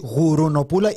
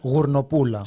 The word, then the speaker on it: γουρνοπούλα: Όπα, λέει γουρουνοπούλα ή γουρνοπούλα. – Είναι διαφορετικά πράγματα, έχω αυτή την απορία. – Είναι βουβό αυτό, δεν προφέρεται αυτό. – Ωραία. – Λέγεται γουρνοπούλα.